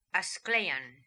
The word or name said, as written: Asklaian